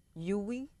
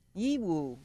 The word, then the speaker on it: Yiwu: It says, I don't know how to pronounce this, Y-I-W-U. Yuwi? Yuwu. Yiwu.